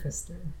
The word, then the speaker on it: Pfister: A. Pfister, yeah. But it's spelled P-F, so good times, good times.